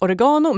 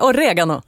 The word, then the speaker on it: oregano: Säger man oregano eller säger man oregano? – Jag tror att i Sverige är det vanligaste uttalet oregano, men det korrekta italienska uttalet är oregano.